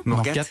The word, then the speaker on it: Morgat: N'hésitez pas à visiter euh, les grottes de Morgat, je prononce bien Morgat.